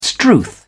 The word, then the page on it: strewth: British English